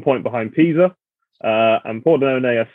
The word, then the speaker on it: Pordenone: and I am taking a home win for Brescia against, the pronunciation is going to be horrible, Pordenone? – Yeah, that's right. – Pordenone.